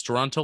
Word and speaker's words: Toronto: Toronto, like the city. That's how he told me to pronounce it.